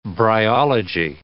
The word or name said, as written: bryology